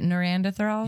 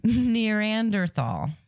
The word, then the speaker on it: Neanderthal: Is it Neanderthal? – Yeah, that's exactly how you pronounce it. – No, it's not. I said it wrong. Neanderthal. Oh, man. Neanderthal. That's better. – Well, it's nearer.